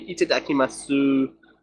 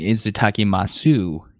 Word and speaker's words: itadakimasu: You know, the funny thing about that word is it actually ends with a U, so you would think it'd be itadakimasu, but it's not.